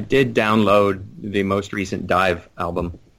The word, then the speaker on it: DIIV: some Beach Fossils and Dive. It's spelled D-I-I-V.